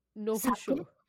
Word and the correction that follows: Nihonshu: Oh! – Nihonshu. – Nihonshu. – Nihonshu. – Nihonshu, yes.